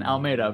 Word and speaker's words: Almeida: Almeida, but thank you.